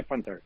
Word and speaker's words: Punter: Pues bueno, depende un poco, pero yo lo pronunciaría Panther.